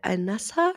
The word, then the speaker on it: Al-Nassr: Und dann ist es ja doch geplatzt, weil es war ja Al-Nassr, spricht man das so aus? – Würde ich jetzt auch so aussprechen.